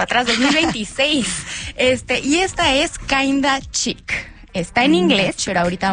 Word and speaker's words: Kinda Chic: En inglés se escribe kinda, es K-I-N-D-A, okay. Y chic, de la palabra que todos conocemos como chic, C-H-I-C. – Muy bien, kinda, kinda chic.